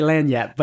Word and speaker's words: lagniappe: Lagniappe. – Some people say lagniappe, but in Louisiana we say lagniappe.